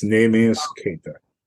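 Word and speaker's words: Naby Keïta: Namius Keita is how you pronounce his name, the player you were alluding to. It's Namius Keita.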